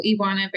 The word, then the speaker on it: Ioana: But yes, it's Baragau, Ioana Baragau.